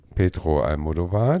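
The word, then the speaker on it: Pedro Almodóvar: Okay, dann hüpfen wir mal zu Petro Almodovar, den yeah!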